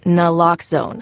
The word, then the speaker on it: Naloxone: For the record, it's Naloxone. Nuh, like, is Naloxone addictive? Nuh. Lox, like Naloxone keeps humans safe, like locks keep bikes safe. And own, like Naloxone can save lives in our very own communities.